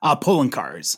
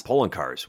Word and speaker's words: Poloncarz: and I believe Ploncaert said, is that how you pronounce his name? – Poloncarz. – Poloncarz, wow.